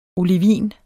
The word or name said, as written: olivin